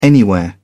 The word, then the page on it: anywhere: British English